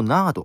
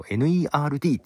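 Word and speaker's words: N.E.R.D.: 俺ずっと NARD って読んでたけど、 NERD っていうアーティストですね。の曲です。 NERD で She Wants to Move。はい、えー、 NERD で She Wants to Move です。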